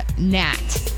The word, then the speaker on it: gnat: That was the word gnat. It has a silent G, in case you're wondering where I went wrong.